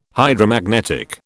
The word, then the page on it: Hydromagnetic: British English